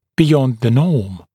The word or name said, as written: beyond the norm